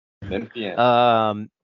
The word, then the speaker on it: Memphian: Another is it a Memphisonian? Is that how you say it? Memphian? – Memphian. – Memphian. There we go.